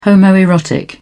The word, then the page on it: homoerotic: British English